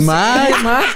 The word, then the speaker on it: Mach: Pues no, Mac.